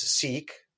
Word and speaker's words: seque: Uh, one was or is seek, like written S E Q U E. I think it's pronounced seek.